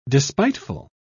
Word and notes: despiteful: British English

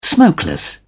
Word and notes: smokeless: British English

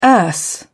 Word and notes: erse: British English

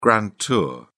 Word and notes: grand tour: British English

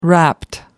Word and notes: wrapped: American English

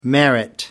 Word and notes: merit: American English

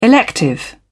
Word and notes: elective: British English

